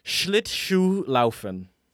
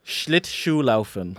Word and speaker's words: Schlittschuhlaufen: Schlittschuhlaufen. – Yeah, that was good. Schlittschuhlaufen. That's very good. Uh-huh. Schlittschuhlaufen.